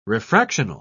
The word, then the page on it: refractional: British English